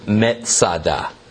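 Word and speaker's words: Metzada (מצדה): Masada is merely a Roman way to pronounce the Hebrew word Metzada, which means fortress.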